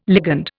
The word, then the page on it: ligand: Indian